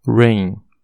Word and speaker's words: rain: Ou staff, S-T-A-F-F. Staff é tipo a equipe ajudando, tá bom? Uh, regra 2, ó. Quando duas vogais estiverem juntas, a primeira será longa e a segunda não será pronunciada. Exemplo: rain, R-A-I-N, R-A-I-N, rain. É, desculpa, eu vou pronunciar de novo que eu não sei se ficou bom. R-A-I-N, rain, chuva.